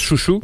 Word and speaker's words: Xiu Xiu: Euh, juste avant, on écoutait donc Xiu Xiu, qu'on prononce Chouchou.